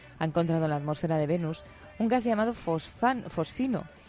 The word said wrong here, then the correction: fosfina: No lo digo bien, ¿eh? Fosfina.